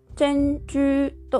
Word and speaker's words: 珍珠: というわけで、このチェンジューという音は 2 つとも高い音、第一声になります。なので、チェンジューと高いトーンで発音してください。